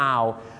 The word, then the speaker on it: hour: hours ถ้าไปออกเสียงว่า 25 hours นี่นะครับผมฝรั่งจะฟังเป็นนกฮูก 25 ตัวนะครับเพราะ owl เนี่ยออกเสียงว่า owl ไม่ใช่ o นะครับ owl นั่นเองครับดังนั้นครับชั่วโมงต้องออกเสียงว่า hour นะครับ